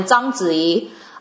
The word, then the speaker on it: Zhang Ziyi: but for me was when Zhang Ziyi, I don't know why, sorry, you guys and gals, I say her name with, like, the Mandarin accent that I have, Mandarin pronunciation. So Zhang Ziyi of the Crouching Tiger, right, Hidden Dragon fame, she's Dr. Chen and she's Dr. Ling, but mostly Dr. Chen.